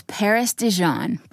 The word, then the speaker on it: Dijon: His name was Paris Dijon, pronounced like the mustard.